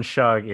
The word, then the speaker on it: Schug: Sebastian Schug, it's it's German, and people uh, people assume that it's pronounced Shug, like you know shoe on your foot, yeah.